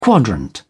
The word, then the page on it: quadrant: British English